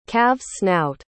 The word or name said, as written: calvessnout